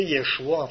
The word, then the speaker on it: Yeshua: Puis nous autres, en français, on peut le prononcer Yeshua en français.